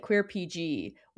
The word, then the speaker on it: Queer RPG: I was also thinking how funny the name of our podcast is, because like it's queer we pronounce it Queer PG, while technically it's queer RPG, and we just made the R one thing, so we pronounce it queer PG.